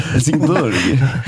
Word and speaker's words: Helsingborg: Helsingborg? Jag hade ingen aning om det. Uttalas det så? – Helsingborg.